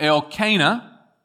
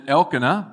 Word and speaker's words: Elkanah: And on the day when Elkanah, some might pronounce it Elkanah, but Elkanah sacrificed,